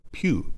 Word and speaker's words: Pugh: said I've been mispronouncing her name forever, so Pugh, Elizabeth Pugh.